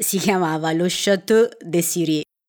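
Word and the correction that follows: Cirey: Comunque è Sirei, lo Chateau di Sirei. Lo Chateau de Sirei.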